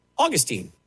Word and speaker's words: Augustine: Augustine or Augustine, depending on how you say his name.